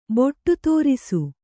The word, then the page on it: ಬೊಟ್ಟುದೋರಿಸು: kannada